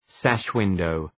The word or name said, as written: sash window